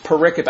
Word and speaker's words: pericope: I know it looks like periscope, missing the S, but it's pronounced pericope. I didn't misspell it. It's not a misspelling. It's the word pericope is how it's pronounced.